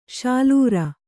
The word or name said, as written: ಶಾಲೂರ